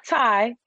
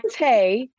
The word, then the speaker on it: Tai: It's Tay, T-A-I, not Ty. It's T-A-I, Tay, yes.